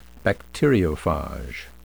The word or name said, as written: bacteriophage